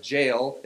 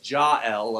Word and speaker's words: Jael: I've been calling her Jael. It's Jael, apparently, so apologies for all that.